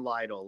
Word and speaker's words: Leidl: So one thing I will say, so I pronounce my name Leidl, like Billy Leidl. Nick Leidl.